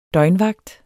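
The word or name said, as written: døgnvagt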